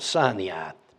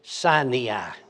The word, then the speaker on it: Sinai: Brother Hagin said Sinai. So in honor of Brother Hagin, I say Sinai. Remember how you say it? Sinai? Remember he'd say Sinai. So I just do that in honor of him.